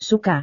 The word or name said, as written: suka